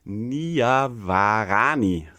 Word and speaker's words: Niavarani: wo der Michael Niavarani, so spricht man nämlich aus, zu Gast war.